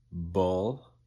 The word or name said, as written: bull